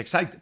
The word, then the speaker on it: Exide: Se escribe Exide, se pronuncia Exide, decía la...